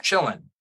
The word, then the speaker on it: Qilin: It's spelled with a Q, or at least in English, it's spelled with a Q. Yeah. I looked it up, or at least a little bit of what it, it is a Chinese mythological being with those powers of good kind of thing. And it's spelled like K or Q-I-L-I-N or something like that, pronounced Chillin'.